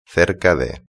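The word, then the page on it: cerca de: Spanish (Spain)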